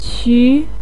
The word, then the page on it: qu2: pinyin